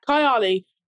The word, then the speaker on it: Kayali: Is it, I don't know how to pronounce it. It's Kayali. – Yeah. – Kayali.